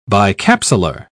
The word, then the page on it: bicapsular: British English